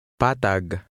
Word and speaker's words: Patag: Without an accent mark, stress is consistently on the second to last or penultimate vowel in a word, slightly lengthening the vowel sound. Try to read number 4. – Patag.